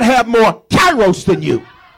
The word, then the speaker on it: kairos: Say kairos. Now I'm saying that with a little country vernacular. It's like kairos.